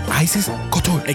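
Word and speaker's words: Icesis Couture: ¿Cómo se pronuncia? Isis Couture. Es que está, está escrito diferente.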